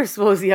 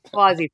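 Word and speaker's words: Suozzi: I think the message you heard from, so it is Swazi or Swozy? I'm sorry. I've also struggled with this. – I'm taking Gabe's pronunciation, Swazi. – Swazi. Thank you.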